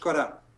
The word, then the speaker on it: Euskara: How do you say the name of the language in Basque? Euskara. – Euskara.